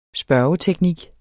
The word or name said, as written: spørgeteknik